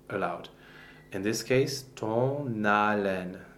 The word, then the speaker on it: haleine: So the word for breath, haleine, the H is not pronounced. There is no sound that goes with an H in French. It's a silent consonant.